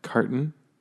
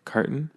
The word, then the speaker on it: carton: Remember this: the difference between this word cartoon and there's another word carton, which actually you don't pronounce the T that hard, but carton is how I say it, carton. That's with like one O. So don't get those two mixed up. This one is cartoon, where you do pronounce the T. Cartoon and carton, like a carton of milk.